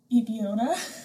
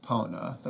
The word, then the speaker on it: Epona: I think it's probably pronounced Epona, right? – Epona. That's how I say it.